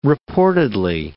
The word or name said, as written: reportedly